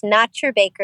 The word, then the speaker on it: NaturBaker: There's no E in nature. So that's Nature Baker is actually the pronunciation, but I never tell people that. So it's NaturBaker, Nature Baker.